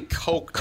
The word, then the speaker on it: Koch: K-O-C-H. – K-O-C-H. – Really? – Oh. Yeah. – It would be Coke. – Yeah. Well, that's how he pronounces his own name.